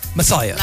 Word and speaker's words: Messiah: Now, I want to ask you, actually, how do I pronounce it? I know it's spelled Messiah. – A certain way. Messiah. – Like the Messiah. – Like the Messiah that manifests.